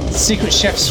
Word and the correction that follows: Secret Chiefs: Chefs? Are you determined to like just mispronounce every single artist? The Secret Chefs. – Chiefs. – Oh, I've never heard of that. – Chiefs. – Secret Chiefs 3.